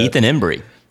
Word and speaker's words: Embry: Ethan Embry. – Yeah. Ethan Embryo. Dumbass. – Ethan Embryo. – Say it right.